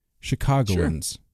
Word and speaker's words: Chicagoans: How do you how do you say that? Chicagoans? – Chicagoans. Sure. Yeah. That sounds right."